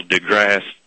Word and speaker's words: deGrasse: I think Mr. Neil deGrasse, is that how you say it, Tyson? – Yeah.